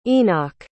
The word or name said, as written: enoch